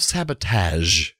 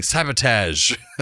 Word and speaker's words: sabotage: This is the famous episode where you hear Kirk saying sabotage with the huge wrong pronunciation. – Did you say sabotage? – And Spock says it correctly multiple times, and then William Shatner continuously says sabotage.